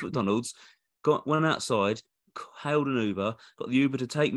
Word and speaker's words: Uber: It's pronounced Uber.